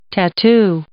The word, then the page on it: tattoo: noun